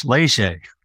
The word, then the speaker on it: Léger: You're Canadian. You should know it's French, Léger.